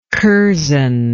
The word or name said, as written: curzon